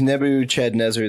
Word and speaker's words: Nebuchadnezzar: It was Nebuchadnezzar II. – It very well could have been. – Thank you for pronouncing it correctly. – But Nebuchadnezzar.